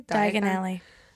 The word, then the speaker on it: Diagon Alley: Diagon Alley.